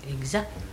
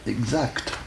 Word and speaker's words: exact: Voyez-vous, sans que te. Voyez-vous? Et alors, de plus en plus, sous l'influence toujours de l'orthographe, n'est-ce pas? La prononciation avec que te cherche à se généraliser, voyez-vous? Oui, remarquez ça. Il y a beaucoup de gens plus ou moins âgés qui prononcent exa. la jeune génération exacte, plutôt.